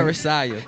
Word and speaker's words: Terracite: Terracite. – Terracite. – Yes. – Okay. Like Messiah.